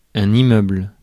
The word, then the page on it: immeuble: ɛ̃.n‿i.mœbl; France